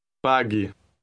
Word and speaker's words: pague: Listen and repeat word 15. – Pague. Pague. – Note that the U is silent.